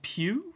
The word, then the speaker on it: Pugh: And then Florence Pugh, is that how you pronounce it? – Yeah.